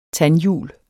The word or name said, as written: tandhjul